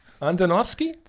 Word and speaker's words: Andonovski: Andonovski. Andonovski. An- Andonovski.